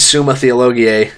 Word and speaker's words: Summa Theologiae: But Thomas Aquinas, in uh, his Summa Theologiae, um, he's That's a really good pronunciation of that.